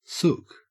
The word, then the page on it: sook: Australian English